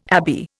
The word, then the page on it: abbey: Indian